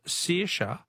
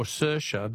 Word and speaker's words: Saoirse: Helen from Footscray says, Phil, the Irish name Saoirse is pronounced either Saoirse or Saoirse, depending on which county you come from.